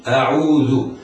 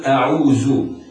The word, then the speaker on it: أعوذ: والذال حرف لثوي يخرج من طرف اللسان وأطراف الثنايا العليا هكذا أعوذ أعوذ، كما علمنا مشايخنا ونحن أطفال يقولون لنا أخرج لسانك عند نطق هذا الحرف وهو الذال، فليحذر القارئ من إعطائه صفيرة الزاي فلا ينطق خطأ أعوذ.